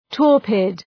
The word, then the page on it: torpid: ‘tɔ:rpıd